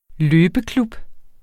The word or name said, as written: løbeklub